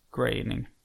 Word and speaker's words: Groening: Så då läste jag att det ska uttalas som färgen grå. Groening.